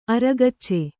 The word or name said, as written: ಅರೆಗಚ್ಚು